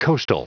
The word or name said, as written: coastal